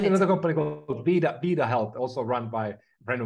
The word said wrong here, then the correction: Virta Health: So we got Vita Health, Virta Health. I just wanted to correct the pronunciation on that.